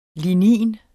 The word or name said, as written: lignin